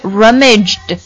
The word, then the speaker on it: rummaged: 那加了 d 之后呢，就发一个的音呢，rummaged the ship。